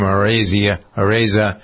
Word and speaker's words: Araiza: Matt Araiza, I believe is how you pronounce his name, Araiza,